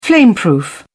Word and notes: flameproof: British English

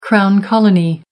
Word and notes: crown colony: American English

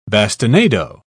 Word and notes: bastinado: British English

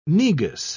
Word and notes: negus: British English

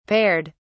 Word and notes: paired: British English

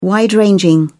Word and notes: wide-ranging: British English

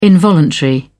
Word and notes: involuntary: British English

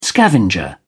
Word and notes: scavenger: British English

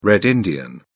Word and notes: red-indian: British English

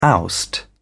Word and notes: oust: British English

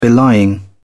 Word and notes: belying: British English

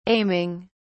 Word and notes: aiming: British English

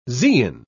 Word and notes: zein: British English

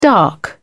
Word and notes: dark: British English

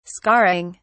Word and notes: scarring: British English

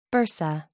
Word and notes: bursa: American English

